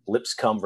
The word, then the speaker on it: Lipscomb: And I, at no point is it ever like, oh, let me sit down and watch, you know, Lipscomb versus Farley Dickson.